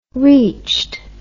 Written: reached

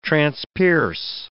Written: transpierce

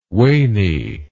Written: waney

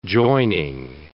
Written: joining

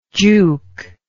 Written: jook

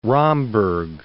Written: Romberg

